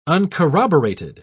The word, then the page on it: uncorroborated: British English